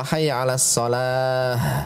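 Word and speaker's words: حي على الصلاة: Kalau hayya 'alas shalah, nah, di akhir pakai ha, ha besar pengucapannya, karena tak marbutuh kalau kita sukunkan dia menjadi ha, ha besar ya, keluarnya dari tenggorokan bagian bawah ha besar.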